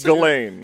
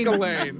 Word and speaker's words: Ghislaine: It's Ghislaine. – Ghislaine. – Ghislaine. The S is silent.